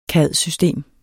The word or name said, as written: cad-system